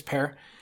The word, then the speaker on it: Per: His name is Per, which he was very clear about saying is pronounced like the fruit pear.